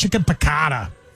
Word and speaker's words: chicken piccata: Maggiano's has got chicken piccata.